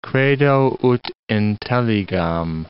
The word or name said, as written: credo ut intelligam